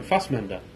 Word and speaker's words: Fassbender: You said his name right, Fassbender.